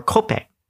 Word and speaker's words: kope: The loan word of coffee in the Hawaiian language is, and it's pronounced, cope, or kope, or cope. Yeah, cope. There you go, cope.